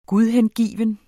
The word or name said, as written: GUDHENGIVENT